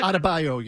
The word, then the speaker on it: Adebayo: Now, Adebayo, is that how you pronounce it? – Adebayo, yeah.